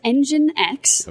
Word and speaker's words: NGINX: Uh, it is Engine X.